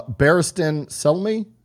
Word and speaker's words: Barristan Selmy: Barristan Selmy? Is that how you pronounce that? – Oh man. Yep.